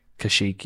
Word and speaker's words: Kashyyyk: Kashyyyk, yeah.